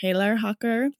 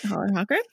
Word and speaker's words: Hollerhocker: Another cheese that we especially recommend for the holidays is called Hollerhocker, which I'm pronouncing in the American way. If I were really Swiss, it would probably be much more like Hellerhocker, but I'm not Swiss, so I'm going to say it Hollerhocker.